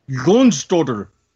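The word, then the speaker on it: Jónsdóttir: They got a big Icelandic name, and I'm going to butcher this, Gunni Sjost... Well, I'm not even saying that. John's daughter, I guess. Seriously, yeah, that's what it looks like. – If I can have that spelled in front of me, I can... – Jonsdottir. – Jonsdottir. – Thank you, for the probably more correct pronunciation than what I just gave it.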